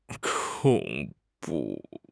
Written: 恐怖

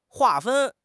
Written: 划分

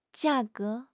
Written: jia4 ge2

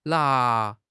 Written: na2

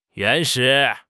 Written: 原始

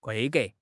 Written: 规格

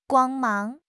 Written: 光芒

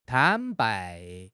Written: 坦白

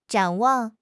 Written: 展望

